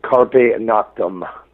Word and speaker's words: Carpe Noctem: I don't even know if my Latin is proper, but it's Carpe Noctem.